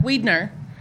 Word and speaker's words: Widener: And I know how to pronounce his last name, even though it's spelled like Widener. I know it's Widener because what street did Mary Margaret grow up on? – Widener?